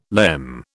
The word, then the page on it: lemme: British English